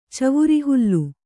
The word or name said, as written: ಚವುರಿಹುಲ್ಲು